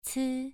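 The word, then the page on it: ci: tone 2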